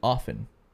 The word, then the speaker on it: often: But she tells me that it's actually often. – No, I would say it's often. – Yeah. – Yeah, often. – She says the T is silent.